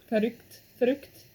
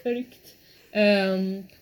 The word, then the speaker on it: Verrückt: Is it called Verrückt? – Verrückt. – Yeah. – I should have... I feel like your German pronunciation was better than mine. But Verrückt.